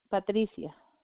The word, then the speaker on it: Patricia: It's Patricia.